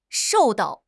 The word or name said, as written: shou4 dao4